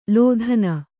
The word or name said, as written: ಲೋಧನ